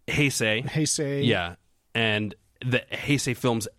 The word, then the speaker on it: Heisei: H-E-I-S-E-I? – Yeah.